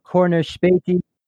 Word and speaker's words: Corner Späti: Corner Späti.